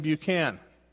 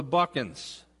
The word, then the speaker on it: Buchan: And so I started pronouncing my name, Buchan, in the North. And so it caught on. So my brothers and sisters who grew up in Chicago and their children and their children's children all pronounce our name Buchan. But if we take a trip south of the Mason-Dixon, all of a sudden we're the Buchans.